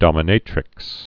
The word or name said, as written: dominatrix